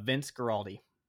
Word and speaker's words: Vince Guaraldi: Vince Guaraldi.